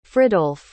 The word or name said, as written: fridolf